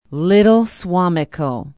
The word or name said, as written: Little Suamico